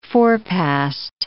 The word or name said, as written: forepast